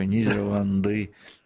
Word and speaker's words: Нидерланды: и мне нравится вот ударение на коне в конце, такое что-то степное, такое Нидерланды,